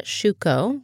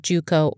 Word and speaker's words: Shuko: There was a guy named Morata Shuko, which could also be pronounced Juko or Shakao.